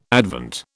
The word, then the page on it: Advent: British English